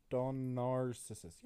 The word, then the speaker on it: Pseudonarcissus: Pseudonarcissus. Yeah. Because the P is silent. You don't say the P.